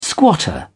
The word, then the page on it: squatter: British English